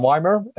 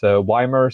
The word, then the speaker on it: Ymer: Oh, I'm Weimer. I am Weimer on the Select Button forums. That's Weimer spelled Y-M-E-R. So it's probably not how many would pronounce it.